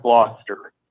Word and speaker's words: Gloucester: Is that Gloucester the way it's spelt? But it's pronounced Gloucester.